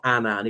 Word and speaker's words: Annan: And Kofi Annan, the former Secretary General of the United Nations, came up with the Annan Plan. By the way, it's not Annan. He said it's Annan to rhyme with cannon.